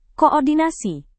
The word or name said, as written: koordinasi